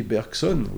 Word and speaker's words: Bergson: There's another example, you know, the famous philosopher Bergson, Henri Bergson, we pronounce it that way,